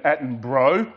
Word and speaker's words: Attenborough: I am a huge fan of David Attenborough. I deliberately pronounced that with the bro on the end.